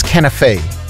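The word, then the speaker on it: kunafa: I said it was Kanaf. Apparently it's Canafe. – Okay. – It's Kanaf. – Oh, Fraser said it's Canafe.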